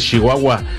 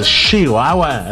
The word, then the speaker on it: Chihuahua: Saludos a toda la gente, a todos los amigos, hermanos que conocí por allá en Ciudad Juárez, Chihuahua. Chihuahua, así dicen ellos, allá es Chihuahua. Ellos te pronuncian más, muy marcada la She.